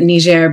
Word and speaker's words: Niger: You know, so I was in Niger, and I pronounce it Niger because it's the French pronunciation. Yeah, that's the colonial language in Niger.